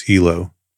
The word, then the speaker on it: helo: In the United States military, the common slang is helo, pronounced with the long E.